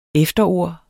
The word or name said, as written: efterord